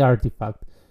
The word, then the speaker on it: RTFKT: que en realidad se pronuncia NFT Artifact, que es un estudio bastante famoso del mundo de los NFTs.